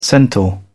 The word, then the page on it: centaur: British English